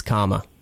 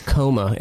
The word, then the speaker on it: Comma: I've had a number of people say coma, and I said, sorry, we are really alive and well and kicking here. Coma is spelled with one M and comma with two M's, so definitely they're not the same.